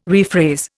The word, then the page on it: rephrase: Indian